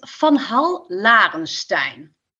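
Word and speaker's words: Van Hall Larenstein: The pronunciation is Van Hall Laurenstein, but you did a really good job.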